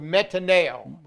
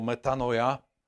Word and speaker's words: metanoia: A samozrejme, robte pokánie, to slovo is the Greek word metanoia. – Poznáte grécke slovo metanoia? – You didn't say it like I did. – Metanoia. – Well, that's probably more right than mine. – I think so.